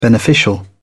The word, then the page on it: beneficial: British English